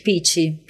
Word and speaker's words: Pitch: É, se pronuncia Pitch? Pitch. Pitch Group.